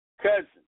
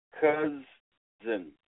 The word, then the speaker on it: cozen: I'm sorry, that's not right. It's cousin. Cousin. Not that kind of cousin, but this is the way it has the pronunciation. It's a verb, and it's cousin, cousin, C-O-Z-E-N, cousin, all right?